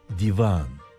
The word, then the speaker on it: Divan: Bu anlamda olanın söylenişinde i kısa, a uzun. Divan. Kimi kaynaklarda başka okunuşu da önerilmektedir ama genel kabul divan şeklindedir.